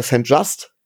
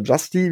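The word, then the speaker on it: St-Juste: St. Just oder Justy, wie er ausgesprochen wird, den Cornerback, weil ich nochmal sage, das Football-Team braucht eigentlich auch Receiver-Verstärkung.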